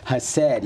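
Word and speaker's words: hased (חסד): So how is that word pronounced and what does it mean? – Okay, yes, hased, yes, and this is great.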